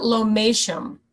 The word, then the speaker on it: Lomatium: And there's something called, do you say Lomatium? Is that how you say it? – You pronounce it Lomatium. – Lomatium, okay. – Lomatium, yeah. – Yeah, it's yeah. It's spelled Lomatium, but you say Lomatium.